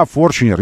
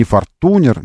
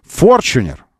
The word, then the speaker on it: Fortuner: Да, Fortuner, именно так произносится, потому что специальные люди под специальным бдительным надзором японских специальных людей, специальные русские люди учили журналистов, специальный мастер-класс организовали, учили, как правильно называется этот автомобиль. Не Fortuner, не Fortuner, Fortuner. Fortuner, вот так, это каноническое произношение.